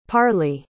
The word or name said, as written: parlee